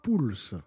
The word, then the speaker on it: Poels: Comment La prononciation je C'est Pouls. Pouls. Maintenant, ça sera Poils. Voilà. Bon, après, je pardonne, mais c'est Pouls en Belgique, oui. et en France, on dit Pouls.